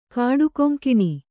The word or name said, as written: ಕಾಡುಕೊಂಕಿಣಿ